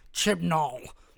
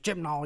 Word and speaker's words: Chibnall: Chibnall. – My name's Chibnall, y'all.